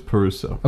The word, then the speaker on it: Parisot: P-A-R-I-S-O-T? The T, you don't pronounce the T, right? – S-O? – Yeah, I believe it's Peruso.